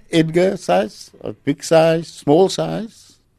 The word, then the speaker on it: Syce: It's spelled S-Y-C-E. – S-Y-C-E. Okay. – Edgar size. I make a little rhyme out of it. Big size, small size, doesn't matter what size, but I'll rise to the occasion because I serve a big God.